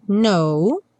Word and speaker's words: know: Because you don't know. No don't know, a gente não pronuncia nem o T do don't, nem o K do know, porque a gente nunca pronuncia mesmo o K do know.